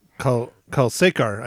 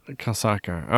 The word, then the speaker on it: Kalsekar: Ashwini Kalkashkar? – Kalsekar, I think. – Kalsekar. Okay. Anyway.